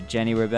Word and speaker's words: Jenny Rube: I'm joined by Jenny Rube and Denea Campbell.